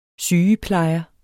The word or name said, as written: SYGEPLEJERE